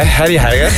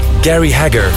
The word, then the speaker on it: Gary Hager: Jij als West-Vlaming, hoe spreek je dat uit? – Ik hoor je al komen. Kwist het, hè? Harry Hager. Harry Hager met... Nee, ik kan het ook perfect, hoor. Gary Hager.